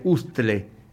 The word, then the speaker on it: hustle: se escribe con H, hustle, pero se pronuncia hustle, el hustle.